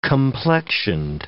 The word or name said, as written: complexioned